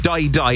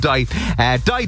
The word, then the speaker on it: Dai Dai: Not Die Die, it's spelled D-A-I-D-A-I, so not Die Die as in die. Die Die is the name of the song,